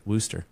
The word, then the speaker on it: Worcester: But it's Worcester. You know, that's a town in England, and they pronounce it Worcester.